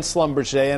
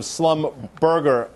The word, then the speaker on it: Schlumberger: Joker, and the triple in terms of Schlumberger. You mentioned it. You pronounce it much better than I, but in the business, we used to call it Schlumberger, man.